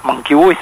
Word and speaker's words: Mankiewicz: Mankiewicz, Mankiewicz, se pronuncia Mankiewicz, se quería postular como candidato a secretario del SOEM, el mismo que tuvo Balcedo, ¿se acuerdan ustedes?